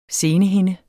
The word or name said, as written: senehinde